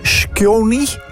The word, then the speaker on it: Skjoni: Shoki, hoe spreek je dat uit, zeg? Skjoni, 31. Dat is heel moeilijk. S-K-J-O-N-I.